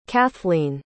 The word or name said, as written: cathleen